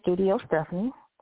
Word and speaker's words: Studio Steffanie: And so again, my name is Studio Steffanie, and my name is spelled S-T-U-D-I-O-S-T-E-F-F-A-N-I-E.